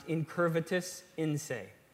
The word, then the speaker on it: incurvatus in se: Martin Luther, one of the great reformers in the 1500s, he had a Latin phrase, and it's pronounced incurvatus in se.